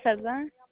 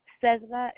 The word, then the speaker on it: Cerda: It's Hilda Cerda. – Can you spell that for me? – Hilda is H-I-L-D-A. Cerda is C-E-R-D-A. – And the last name? – It's C-E-R-D-A.